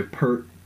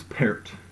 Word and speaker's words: Peart: A lot of people get his last name wrong because they just don't know how to say it. They say Peart. It's Peart.